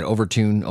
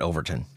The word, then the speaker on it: Overtoun: So I'm going to go with what the locals say, how the locals pronounce it. If you ever have difficulty pronouncing something, just go with what the locals are saying. So they tend to lean toward, like I said, Overtoun, Overtoun, but we're going to call it Overtoun.